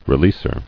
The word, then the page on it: Releaser: re·leas·er